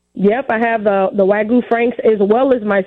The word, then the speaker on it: Wagyu: You know what's cool about you is not only do you have Lexington Betty Smokehouse, but your Wagyu. Do I say that right? – Wagyu, yeah, you're pretty close. Wagyu beef.